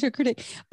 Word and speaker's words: charcuterie: The fuck is that's how you say it, charcuterie.